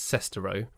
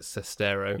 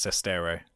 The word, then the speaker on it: Sestero: I believe it's pronounced, in my head, it's Sestero. But I believe he pronounces it Sestero. – Yeah, I think it's Sestero.